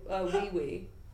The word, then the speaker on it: Weiwei: Also, it's pronounced wee-wee. – Is it really? – Yeah. – I didn't know that. – Wait, what were you saying? – Ai Weiwei. – Weiwei? – He's a Chinese artist. – Ai Weiwei. – Weiwei. – No, it's apparently pronounced wee-wee.